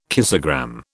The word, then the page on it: kissogram: British English